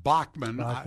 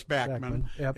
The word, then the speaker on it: Bachman: And, you know, some people pronounce it Bachman. It's Bachman.